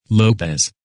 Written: Lopez

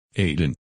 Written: Aidan

